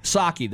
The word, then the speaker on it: Psaki: Patsky, who's the redhead girl who's the press secretary? – Psaki, the P is silent.